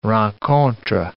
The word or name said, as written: rencontre